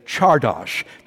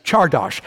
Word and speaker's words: csárdás: The Rondo theme itself, the refrain, is in the style of a three-step Hungarian dance called a csárdás. That's spelled C-Z-A-R. D-A-S, csárdás. Everyone say that, please. Csárdás. As I never tire of pointing out, it's impossible to say csárdás without sounding like Sean Connery.